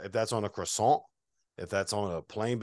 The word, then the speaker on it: croissant: Yeah, croissant. Croissant. That's pretty good. That's how you say it.